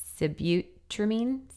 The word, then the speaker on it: sibutramine: Um, I'm going to pronounce it as sibutramine. – Sibutramine. about right.